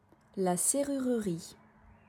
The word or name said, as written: La serrurerie